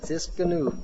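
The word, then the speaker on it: Tsidkenu: Tiskanu. Good. Only a Jew could pronounce that right.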